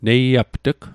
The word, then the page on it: neiapt'g: Mi'gmaq